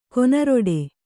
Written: ಕೊನರಿಡು

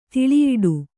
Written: ತಲೆಯುಡೆ

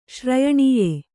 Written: ಶ್ರಯಣೀಯ

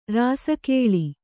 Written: ರಾಸಿಗೊಳ್

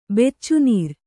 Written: ಬೆಚ್ಚುನೀರು

